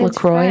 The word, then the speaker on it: LaCroix: How do you say this? – LaCroix. – LaCroix? – Yeah. – Is that how you guys say it? – It's LaCroix. French.